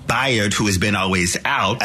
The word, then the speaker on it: Bayard: So Bayard, okay, is how you pronounce his name.